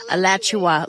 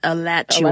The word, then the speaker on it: Alachua: Alachua, like Joshua, but Alachua.